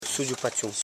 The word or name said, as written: su.dgu.pa.chon